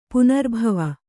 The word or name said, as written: ಪೌನರ್ಭವ